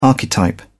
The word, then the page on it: archetype: British English